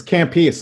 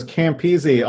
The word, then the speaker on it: Campese: Sure. It's Campese. It was Campese off the boat. Changed it to Campese, yeah.